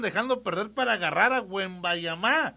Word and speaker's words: Wembanyama: dicen los franceses, que se pronuncia Wembanyama. – Ah, bueno. – No es Wembanyama, es Wembanyama.